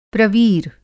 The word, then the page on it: प्रवीर: Hindi